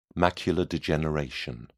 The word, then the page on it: macular degeneration: British English